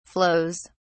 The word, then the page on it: flows: British English